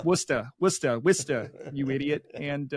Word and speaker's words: Worcester: So there's a city in Massachusetts that is pronounced Worcester. some variation of that. But it's spelled Worcester. Worcester. It's spelled Worcester. – I didn't know that. – 99% of the country, you would read it phonetically Worcester.